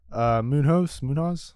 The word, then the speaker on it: Munhoz: But heading into the main card, we have Chito Vera versus Pedro Munhoz, I think is how you pronounce it.